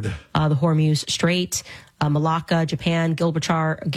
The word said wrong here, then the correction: Gibraltar: Gil, I always say that wrong. Gilbert. You guys know. Gibraltar.